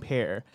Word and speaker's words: Peer: Borzen00 writes in and says, good morning, blessing, and pair. – P-E-A-R. I like it. – He misspelled both. – No, it's all good. But that's the right pronunciation.